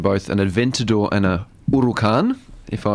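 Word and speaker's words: Huracán: It's a silent H. Huracan, it is. But we would say Huracan.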